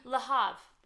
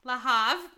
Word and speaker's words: Le Havre: Le Havre. – Le Havre.